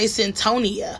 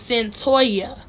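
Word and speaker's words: Cyntoia: The cover of Centennia Brown's, I don't know why I always want to say Centonia, because it's not. It's spelled Sin, C-Y-N, like Sin, like Cynthia, I guess, short for Cynthia, but it's Centoya. Oh, is it Centoya? That would make sense, because the toy, T-O-I, and the A is like Centoy.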